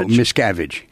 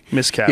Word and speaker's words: Miscavige: What's the correct pronunciation? It's Cavage? Miscavige? – No, Miscavige. – Miscavige.